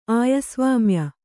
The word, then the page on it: ಆಯಸ್ವಾಮ್ಯ: āyasvāmya